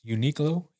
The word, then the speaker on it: Uniqlo: His was up there, I guess, with quality, because he used, how do you say it? It's Uniqlo.